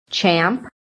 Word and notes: CHAMP: American English

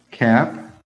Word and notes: CAP: American English

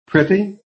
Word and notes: PRETTY: American English

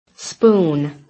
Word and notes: SPOON: American English

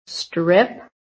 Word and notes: STRIP: American English

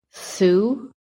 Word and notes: SUE: American English

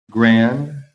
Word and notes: GRAND: American English

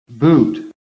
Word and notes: BOOT: American English

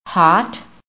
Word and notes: HOT: American English

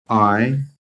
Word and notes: I: American English

